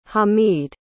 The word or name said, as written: hameed